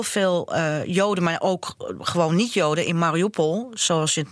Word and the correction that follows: Mariupol: net uit, dus ik legde de klemtoon verkeerd. Hè? – Mariupol.